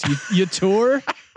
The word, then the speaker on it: Yetur: But I think Yetur Gross-Matos, Yetur. I just pronounce it like neuter, but Yetur Gross-Matos, he kind of fits that Seattle defense.